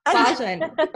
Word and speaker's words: Bajan: Did I say that right? Is it Bajan? But that's cool. – Bajan. I sounds too. Bajan. Girl. Bajan. I'm from LA. Anything that looks Spanish is going to be said like it's in Spanish.